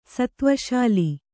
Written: ಸತ್ತ್ವಶಾಲಿ

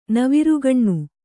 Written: ನವಿರುಗಣ್ಣು

